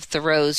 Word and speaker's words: Thoreau: And in a 1980 letter from Edward Emerson, son of Thoreau's friend Ralph Waldo Emerson, "We always called my friend Thoreau. The H sounded an accent on the first syllable," he wrote.